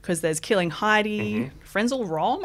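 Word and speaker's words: Frenzal Rhomb: Friends All Wrong. They're huge. Yeah, they were huge for me back in the day. – Because I know who they are, but I've never been confident in pronouncing the name. – And you nailed it.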